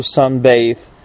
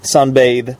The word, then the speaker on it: sunbathe: Uh, the correct pronunciation is to sunbathe. That sunbathe. That's when you lie in the sun, enjoying the sunshine, and uh, maybe your aim is to get a suntan or something. Okay, the pronunciation is to sunbathe. So there is this the the sound, the one that you have in the words like mother, clothes, or father, uh, or the the definite article the.